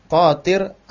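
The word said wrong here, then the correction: tathir (تطهير): Ya, itu bacanya tathir ya, tathir An-Najasat.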